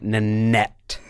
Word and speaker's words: Nanette: It's disarming to hear you say Nanette without really pronouncing it. Nanette. – Nanette. It's, you want to whisper it.